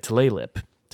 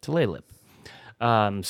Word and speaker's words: Tulalip: It's spelled Tulalip. Tulalip. Tulalip. So yeah, busy weekend for the band.